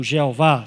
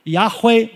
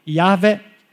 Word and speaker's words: Yahweh: Yahweh, ou Yahvé, ou Jeová. Você vê que Jeová é uma, uma invenção, tá? Mas Yahweh ou Yahvé é, é a pronúncia mais apropriada.